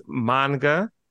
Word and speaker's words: manga: Yeah, by the way, it is manga and it's Japanese comics and graphic novels.